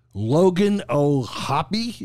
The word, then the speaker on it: Logan O'Hoppe: Logan O'Hoppe is his right pronunciation.